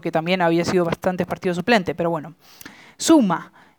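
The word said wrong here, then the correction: Zouma: Eh, Zuma, que es la correcta pronunciación, tuvo un malísimo partido, pero muy mal, muy mal partido.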